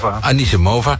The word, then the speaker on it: Anisimova: Want tennisser Amanda Anisimova, zeg ik dat goed? – Anisimova ging viral nadat ze een biertje leek te drinken tijdens een wedstrijd.